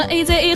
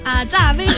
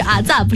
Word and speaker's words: AZA: 所以很多人在刚开始的时候会不会念成？ – 会念成 AZA 合唱团。重申重要的事情，阿扎威唱团。 – 对，重申了一下，我们是阿扎，不是 AZA 啊，对不对？